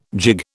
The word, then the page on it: jig: British English